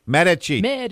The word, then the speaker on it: Medici: And, Patricia, pronounce your last name for me. I get it wrong twice. – Medici. – Medici, because I had a different... – The accent is on the last syllable.